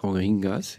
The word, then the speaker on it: Rohingyas: Rohingyas, ja.